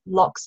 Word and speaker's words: Locke: You can find it on Amazon looking up Animal Kind and my usual name, which is Emma Locke, Locke spelled like a door lock, L-O-C-K.